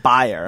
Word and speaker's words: Bayer: It is pronounced Bayer. Uh-huh. And I guess that just sounds like a little bit more severe or harsh.